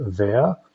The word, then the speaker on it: Vergt: But the town of Vert, which is V-E-R-G-T, it's pronounced Vert, is maybe 2 or 3 miles down the road, which has everything you need.